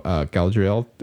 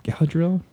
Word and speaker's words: Galadriel: And they can just kind of go, all right, well, we're going to take some time to introduce, you know, Galadriel again. Galadriel? – Galadriel. – Galadriel. I'm like, that's not how you say her name.